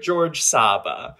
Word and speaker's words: Georges Saba: it's Secret George Saba, but secret is spelled S-E-A-T, cret, George, G-E-O-R-G-E-S, S-A-B-A.